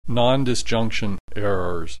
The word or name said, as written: nondisjunction errors